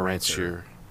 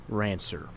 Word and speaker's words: ranseur: It's called a rancer. – Right. – I googled the pronunciation rancer. – How do you spell it? – R-A-N-S-E-U-R. – S-E-U-R.